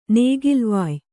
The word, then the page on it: ನೇಗಿಲ್ವಾಯ್: Kannada; nēgilvāy